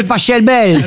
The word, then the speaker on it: Pachelbel: Catelloni, c'est Pachelbel. – S'il est allemand, ça se dit Pachelbel.